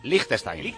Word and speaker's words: Liechtenstein: Alfonso Sánchez está enfadado porque digamos Liechtenstein, que os decimos Liechtenstein con todas las letras, o como se pronuncia realmente, que es Liechtenstein.